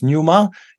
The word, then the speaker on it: pneuma: Is that pneuma? – Yes, pneuma.